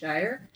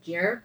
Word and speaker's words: Geyer: Well, the author said it was a soft G sound, like gyroscope. So I assume it's like Geyer. – Geyer. – But I okay. – called her just Jer because I was thinking of like a Jer Falcon because her mom's name is Peregrine.